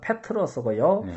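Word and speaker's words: 페트로스고요 (Πέτρος): 이 베드로는 헬라어 발음으로 하면은 페트로스고요. – 네. – 반석은 페트라입니다.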